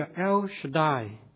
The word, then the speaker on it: El Shaddai: 在原文这个字的发音叫做 El Shaddai，在一首的英文现代的诗歌也是用这个 El Shaddai 这个名字来取名。